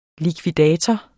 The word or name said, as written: likvidator